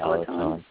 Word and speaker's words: allantoin: Yeah, allantoin.